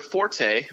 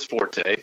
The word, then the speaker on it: forte: And his example for him is that the word forte, meaning your strong suit, is pronounced fort and not forte. And he can die on that hill because he's wrong. It is forte. Forte means strong in music. That's what forte is.